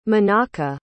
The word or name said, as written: Menaka